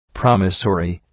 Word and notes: promissory: ‘prɒmısərı

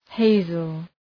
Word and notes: hazel: ‘heızəl

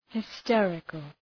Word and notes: hysterical: {hı’sterıkəl}

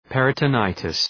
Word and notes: peritonitis: ,perıtə’naıtıs

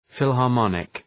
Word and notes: philharmonic: ,fılhɑ:r’mɒnık

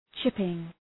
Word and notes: chipping: ‘tʃıpıŋ

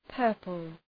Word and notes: purple: ‘pɜ:rpəl

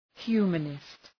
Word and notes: humanist: ‘hju:mənıst

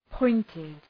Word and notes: pointed: ‘pɔıntıd

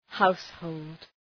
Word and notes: household: ‘haʋshəʋld

